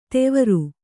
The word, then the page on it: ದೇವರು: Kannada